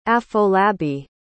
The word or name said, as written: afolabi